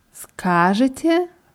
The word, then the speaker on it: скажете: Скажете, weil скажите wäre dann Imperativ. Deswegen ist die Betonung auf dem A wichtig. Как скажете, так и будет wie ihr sagt, so wird das gemacht.